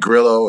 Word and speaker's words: Grillo: For those who don't know, Leon is starring in the film A Day to Die, starring also Bruce Willis and Frank Grillo, I believe is how you pronounce his name. – Frank Grillo and Kevin Dillon. – Grillo, that's right.